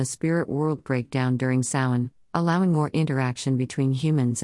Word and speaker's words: Samhain: In modern times, Samhain, a Gaelic word pronounced Samhain,